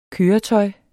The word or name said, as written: køretøj